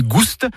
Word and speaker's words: gouste: Ni goût ni gouste, avec prononciation du S originel que l'on retrouve dans le provençal gustus, goûteux, et qui a été remplacé depuis par l'accent circonflexe dans notre orthographe moderne.